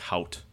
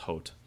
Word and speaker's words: Haute: Um, it takes place in Terre Haute, Indiana, which looks like Hout, but it's actually pronounced Hote.